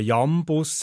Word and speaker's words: Jambus: Ganz im Gegensatz zum Jambus, wo man Jambus sagt, aber ein Jambus bedeutet unbetont, betont. Also, man sagt es nicht so, wie man es meint.